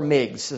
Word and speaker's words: Meigs: But the greatest response of all may have come from a Union firebrand named Montgomery Meigs, or Meigs, as I've heard it pronounced differently.